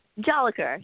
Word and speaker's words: Jolicoeur: Jolicoeur, yeah.